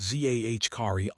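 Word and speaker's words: Zaccharie: The Hawks, for their part, moved on from their former first overall pick, Zachary Risacher, pronounced Z-A-H-Kari R-E-E-Sache.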